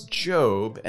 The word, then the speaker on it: Job: wants to know, how do we know that it's Job and not Job? Caleb, in English, this is quite a perplexing challenge, because in any other circumstance, seeing the word spelled J-O-B, you would pronounce it job, as in you're going to work because you have a job.